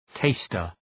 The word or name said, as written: taster